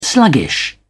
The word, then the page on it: sluggish: British English